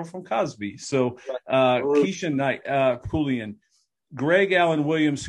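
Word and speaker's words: Pulliam: Keisha Knight and Pullian. Am I saying it right? – Pullian. – Keisha Knight Pullian, who most people will know from Cosby fame.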